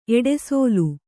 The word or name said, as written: ಎಡೆಸೋಲು